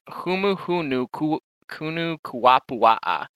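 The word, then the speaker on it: humuhumunukunukuapua'a: It's humu hunu kunu kuapua'a. Like, you don't add H's to it. It's you like you pronounce each syllable in Hawaiian like on its own.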